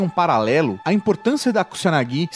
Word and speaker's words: Kusanagi: mandar mensagem criticando a pronúncia. É porque Kusanagi seria, na verdade.